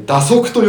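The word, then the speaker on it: 蛇足: これ蛇の足と書いて打足と読む、あの2 本目までで完成しておいてよかったかなって今私サンプル見ながらちょっと反省はしておりますが、ちょっと感想聞きましょうか。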